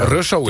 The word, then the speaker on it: Russo: Russo en Mart Hoogkamer, zeg ik dat goed? Want ik heb altijd het idee dat de ene zegt Russo. En nee, Russo. – Russo is het.